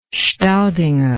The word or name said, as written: staudinger